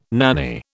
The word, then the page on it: nanny: British English